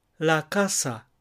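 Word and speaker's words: la casa: で、 3 音節のうち 2番目の音節にアクセントがある、弱強弱というパターンです。ですので、例えばパタッタという 1 個の単語を発音するのと全く同じようなメロディーで、パタッタ、ラカサ、というふうに言えばよいわけです。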